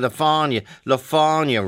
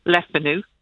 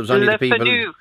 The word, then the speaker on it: Le Fanu: The family pronounce it Le Fanu nowadays. Originally, they were a Huguenot family. They escaped religious persecution. – And what are the other ways? Because obviously the people... – Le Fanu. Le Fanu. if you were going for a more French pronunciation, but the family itself say they don't mind how you pronounce the name at all.